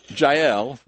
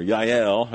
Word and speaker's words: Jael: Then Jael, or Yael, however you want to pronounce her name, Yael. Then Yael, Heber's wife, she took a tent peg.